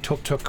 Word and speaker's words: tuk tuk: We have chosen the pronunciation. Tuk tuk, as in I took a tuk tuk.